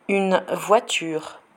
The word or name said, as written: une voiture